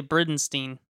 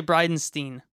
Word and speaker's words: Bridenstine: You also say Bridenstine. Right? Is that how you say it? – No, I say Bryden. What did I say? I don't remember. – You say Bridenstine. – Right, Steen instead of Stein, right? – Right, Steen instead of Stein.